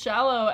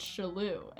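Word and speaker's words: Shallou: Yeah, because I always thought it was Shallow, and then my brother was like, oh, it's Shalou.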